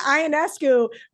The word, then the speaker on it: Ionescu: And one thing we didn't touch on that I thought was pretty cool during the All-Star game, and I'm going to butcher her last name, but it's not my job to know them, unlike you. Sabrina Ionescu versus Steph Curry. – You nailed it.